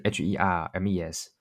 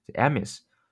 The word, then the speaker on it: Hermès: 呃，首先爱马仕必须要说一下，爱马仕它它的英文虽然是 H E R M E S，但它其实是个法语，它的这个首字母这个 H 是不发音的。所以我你如果要跟别人讲说，呃，爱马仕，你你应该念的是 Hermes。啊，不能念 Hermes 啊！